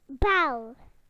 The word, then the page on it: bell: British English